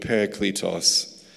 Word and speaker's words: parakletos: So you're going to get a sort of Texan Australian pronunciation of a Greek word, but parakletos.